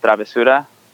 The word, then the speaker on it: travesura: But it's actually a Spanish word for mischief, which is pronounced travesura.